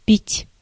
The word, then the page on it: boire: [bwaːʁ]; /bwaʁ/